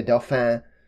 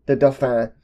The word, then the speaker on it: Dauphin: They were telling her to go to the Dauphin, which I think, again, is how you pronounce that.